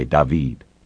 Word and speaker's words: David: She pronounced it the French way, David.